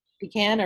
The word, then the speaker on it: pecan: So I definitely hear it around here too pronounced with like an emphasis on the first syllable. So pecan. – Pecan or pecan.